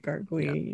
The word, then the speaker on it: gargouille: the French gargouille. I'm probably saying it wrong. – Sounds about right, gargouille, yeah.